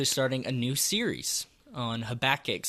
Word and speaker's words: Habakkuk: So first, let me ask you, did I say that right? – Absolutely. – Okay. – Now, I know your wife says it's Habakkuk or something. Poor Katie. – Yeah, so we just need to be clear here on the front end. How do you correctly pronounce it? – Habakkuk. – Thank you. Very good.